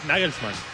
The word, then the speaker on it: Nagelsmann: Es Nagelsmann.